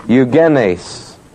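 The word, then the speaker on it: Eugenes (εὐγενής): Oh, you want it spelled? E-U-G-E-N, long E, S. Eugenes.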